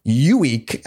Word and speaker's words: Ueeck: To that point, as the conversation veered toward the inevitable town gossip of Mary missing, the farmer named Elmo Ueek, and it's a weird name, literally Elmo, and his last name is spelled U-E-E-C-K.